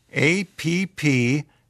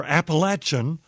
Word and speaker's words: Appalachian: Appalachian is not pronounced with a long A. The correct pronunciation is Appalachia or Appalachian. Almost everyone north of the Mason-Dixon line makes this mistake, and it is a sore point with the natives."